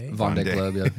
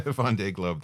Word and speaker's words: Vendée Globe: Vendée Globe. – Yeah. – Vendée Globe.